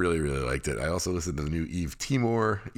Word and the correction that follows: Yves Tumor: Yves Tumor, sorry, The Asymptotical World, Asympt- the symptomatical, asymptomatical world.